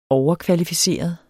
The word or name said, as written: overkvalificeret